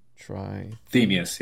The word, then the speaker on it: Trithemius: Or did I say it right this time? Trimetheus? Trithemius? – Trithemius, yeah.